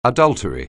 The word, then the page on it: adultery: anglais